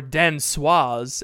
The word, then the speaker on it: danseuse: But I found this fun fact that for some ballerinas or danseuses, as the men are known, not ballerinos. – Are they called danseuses, or are you just saying dancer funny? – Danseuse. Okay. That's how you say it.